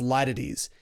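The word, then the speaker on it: litotes: It looks like lytotes if you spell it out. It's L-I-T-O-T-E-S, but it's pronounced lytotes.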